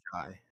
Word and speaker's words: Jai: Michael Jai White. – I think it's J. – It's Jai. – There's no way. No one has ever pronounced that Jai before. – I'm telling you it's Jai.